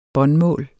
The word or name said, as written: båndmål